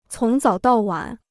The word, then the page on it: 从早到晚: cóng zǎo dào wǎn